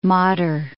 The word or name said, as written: Modder